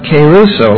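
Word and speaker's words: keruso (κηρύσσω): But this word is keruso, not the singer, but keruso, like this.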